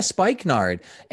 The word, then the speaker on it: spikenard: Is it pronounced spikenard? Spikenard? – Yeah, spikenard.